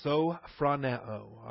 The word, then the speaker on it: sophroneo: Sound mind, sophroneo, all right? S-O, that's the long O, the omega. P-H-R-O, the short O, that's the omicron.